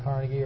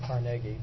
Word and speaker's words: Carnegie: In the steel industry, in item 23, the best-known name, of course, is Andrew Carnegie, or as he's properly pronounced, Carnegie, which I never say, but that's the family wants it pronounced that way.